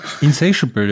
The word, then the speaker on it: Insatiable: I Love You In Me war nicht nur die B-Seite von Arms of Orion, sondern auch von Insatiable, vom Diamonds and Pearls Album. – Was heißt eigentlich Insatiable? Und ist das richtig ausgesprochen? – Insatiable, ja. Insatiable ist eher...